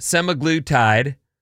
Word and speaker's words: semaglutide: Studies have found that semaglutide, is that how you say it? – Yeah.